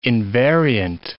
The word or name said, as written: invariant